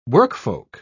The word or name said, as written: workfolk